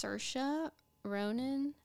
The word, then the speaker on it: Saoirse Ronan: I think I'm gonna maybe butcher her name, but I think it's pronounced Saoirse Ronan. – Yeah, you know Saoirse Ronan.